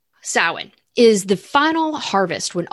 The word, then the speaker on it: Samhain: And you may, if you're just looking at the spelling on that, it's Samhain, but the pronunciation is Samhain, and it runs from October 31st to November 1st.